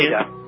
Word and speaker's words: Aida: Is it the Aida refugee camp? – Aida. We pronounce it Aida camp.